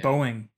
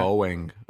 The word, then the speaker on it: Boeing: Boeing. Yeah. – Boeing.